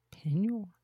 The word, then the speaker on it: Peigneur: Lake Pignor? – Pignor. – P-E-I-G-N-E-U-R in Louisiana.